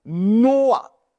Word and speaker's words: Noah: 挪亚这个词其实在原文里面的读音是 Noah，你们听这个读音像不像女娲？